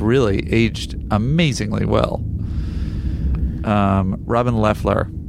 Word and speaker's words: Leffler: Robin Leffler. I think you were saying it wrong. Man, what a cutie patootie she was back. – What did I say, Leafler? – Yeah, she still is.